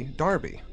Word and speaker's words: Derby: Now, I'm pronouncing it Derby because that's how I would have pronounced it. – I know, but in the UK, they pronounce Derby, Darby.